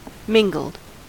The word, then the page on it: mingled: American English